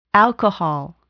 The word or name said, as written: alcohol